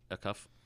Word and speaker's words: Acuff: Acuff. Acuff.